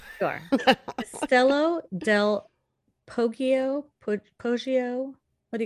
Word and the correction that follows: Castello del Poggio: I would say Castello del Poggio, right? – Yes, that sounds yeah.